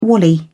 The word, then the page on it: wally: British English